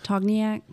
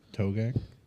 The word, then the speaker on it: Togiak: It's called, starts with a T, T-O-G-I-A-K, I think. – I don't know how to pronounce it. – Togniak? – No.